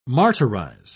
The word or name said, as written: martyrize